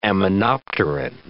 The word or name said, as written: aminopterin